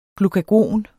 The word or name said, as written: glukagon